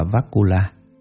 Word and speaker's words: Vakula: Ngài Bakula, Ấn Độ đọc là Vakula, chữ Hán là Thiện Dung.